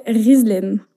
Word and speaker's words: Gislaine: Et euh, est-ce que tu veux bien me donner ton vrai prénom Ouais, alors du coup, je m'appelle Rislaine. Ok. Euh, ça s'écrit Gislaine, donc il y a un prénom euh, assez commun en France.